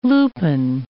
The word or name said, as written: lupin